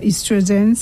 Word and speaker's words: estrogen: Changes in estrogen or estrogen, some persons pronounce it that way, progesterone.